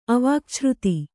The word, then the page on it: ಅವಾಕ್ಛ್ರುತಿ: Kannada